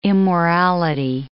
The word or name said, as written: immorality